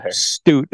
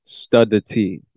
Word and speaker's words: Studt: So it's spelled S-T-U-D-T. – Yes, sir. – Stute is the way to say it.